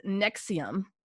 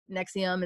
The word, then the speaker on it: NXIVM: So basically there was a cult called NXIVM and it's spelled NXIVM, but not to be confused with the acid reflux medication NXIVM. It's pronounced the same way, but spelled different.